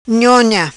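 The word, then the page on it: Nhonha: patua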